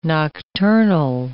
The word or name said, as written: nocturnal